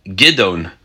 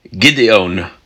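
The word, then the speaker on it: Gidon (גדעון): We learn about a new judge by the name of Gidon, or Gideon, as some like to pronounce it. So let's learn about Gidon, spelled Gimel, Dalet, Ayin, Vav, Nun.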